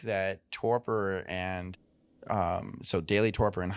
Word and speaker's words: torpor: And there's all the way to, I know years ago I was reading a little bit about torpor, which is that how you pronounce it? Like torpor. – Kind of torpor. – Yep.